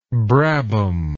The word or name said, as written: brabham